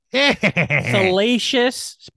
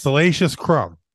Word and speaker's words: Salacious Crumb: You got Salracious Crumb on top of all the Jabba's. – That's his name? That's how you say his name? Salacious. Repeat after me. Salacious. Say it. Salacious. – Salacious Crumb.